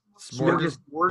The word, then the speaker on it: smorgasbord: Is it Smorgasbord? – Smorgasbord. Board. – Ends with a D or a G? – With a D. – D. – You know the shirt? – Yeah. – Yeah, Smorgasbord Records.